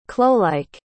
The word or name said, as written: clowlike